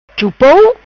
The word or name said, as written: Chupou